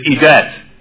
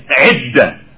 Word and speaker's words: idda: And what usually the English-speaking Muslims say here, they say, idat, idat. But the word is not, the woman is not in her idat. It's idda, idda, not idat. Idda. So let's try to make a tasfiyah on even the pronunciation. It's not, she's not an idat. It's idda.